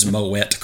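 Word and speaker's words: Moët: And credit to Rakim for twice he references champagne and he pronounces Moet correctly. Because it is in fact Moet and not Moet, despite what you may have heard.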